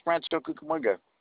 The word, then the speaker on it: Rancho Cucamonga: Why do you say it like that? It's Rancho Cucamonga. Yeah, that's it.